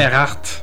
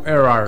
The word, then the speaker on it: Earhart: Moi, je pensais que c'était Earhart. – Ben, c'est ça, c'est prononcé à la française. Okay. C'est correct. Tu as le droit. – Tu peux le prononcer en anglais ou en français. C'est soit Earhart ou Earhart.